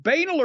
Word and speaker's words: banal: Banal, completely ordinary and commonplace, trite. Is it banal or banal? – It's pronounced both ways, actually. I've heard people pronounce it both ways.